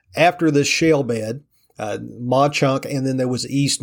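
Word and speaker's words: Mauch Chunk: actually two small communities in Pennsylvania called Mauchunk, and that is spelled M-A-U-C-H space C-H-U-N-K.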